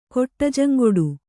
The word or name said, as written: ಕೊಟ್ಟಜಂಗೊಡು